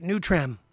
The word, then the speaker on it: Nutram: Nutrum. I feed my dogs kibble. It's called Nutrum. That's right, Nutram. Pronunciation, Nutram.